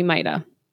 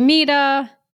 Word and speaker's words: Mita: Not once, not twice, but 3 times during the course of this time I took for this course, the facilitator calls me Mita, a white man. And each time I correct him and I say, it's actually Mita, it rhymes with PETA. You know, I'm trying to phonetically spell it.